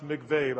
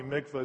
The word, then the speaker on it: mikvah: Sometimes you hear it pronounced mikveh, but I think probably mikvah is probably closer.